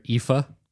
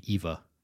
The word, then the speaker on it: Aoife: Name her Aoife. – Mm-hmm. – It's a traditional Irish name. – Yep. – It's like in the modern, it would be Eva. – Mm-hmm. – Eva. – Aoife. – Aoife. It's spelled A-O-I-F-E.